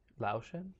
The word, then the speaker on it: Laotian: I think he's, I don't know how to say it, Laotian? – Laotian, Yeah.